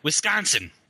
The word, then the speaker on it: Wisconsin: Or if I were to say it like the natives do here, it's Wisconsin, with a very harsh vowel sound in the middle.